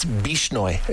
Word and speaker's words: Bishnoi: Okay, speaking of gangs and BC, we're going to get into that in just a second with the, I was calling it the Bolshnoi gang, but I think I was mixing up the old Russian term with the new one. It's Bishnoi, if I've got it correctly.